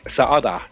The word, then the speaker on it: Saade: And the Swedish entry was popular by Eric Saade. He has two A's, so you have to pronounce them both.